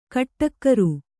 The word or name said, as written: ಕಟ್ಟಕ್ಕರು